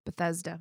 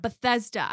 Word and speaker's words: Bethesda: and attended Holton Arms School for Girls in how do you say that? – Bethesda. – Bethesda. I don't like the TH and the S next to each other.